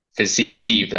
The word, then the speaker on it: Fiziev: I feel Fiziev, like, Fiziev, that's how it's pronounced.